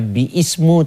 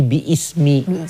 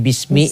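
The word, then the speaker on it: bi ismi (بِسْمِ): Jadi bacanya bukannya bi ismu tapi bi ismi. Kemudian dibuang alifnya jadi bismi, bismi.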